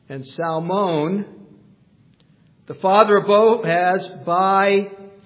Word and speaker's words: Salmon: She marries a guy named Salmon, although in English it looks like the fish salmon, which you don't pronounce the L, that's confusing. But salmon, it's okay if you say salmon.